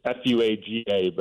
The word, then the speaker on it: Fuaga: It's Fuaga, but it's, you know, the Polynesian, there's the Anga. Yeah. It's a whole thing. I asked him how to pronounce his name at the Senior Bowl, so it's the only reason I know how to say it.